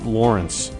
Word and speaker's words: Lawrence: We'll be right back with the Carolyn Lawrence three-of-a-kind showcase, and that's how she prefers to pronounce it, Lawrence.